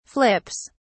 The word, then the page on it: flips: British English